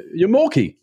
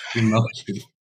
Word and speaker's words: yarmulke: Yarmulke. – Yarmulke. It's definitely yarmulke, not yarmulke.